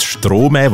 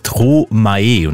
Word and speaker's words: Stromae: Als ich jung war, das war etwa gestern, haben wir immer die Diskussion gehabt, wie spricht man jetzt den jungen Mann aus? Ist es Thomä? Ist es Stromä? Was ich auch mal gehört habe, dachte, okay, das ist es definitiv nicht. Es ist Thomae.